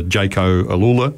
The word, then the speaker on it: Jayco AlUla: When I look at the bikes too, like if I look at the giant bikes racing in the Jayco Alula team, have I said that right? – Correct.